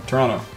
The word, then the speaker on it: Toronto: You even said it right. You didn't then say the other T. – Mm. – How do you say Toronto? – Toronto.